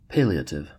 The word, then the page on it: palliative: American English